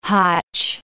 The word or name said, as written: hotch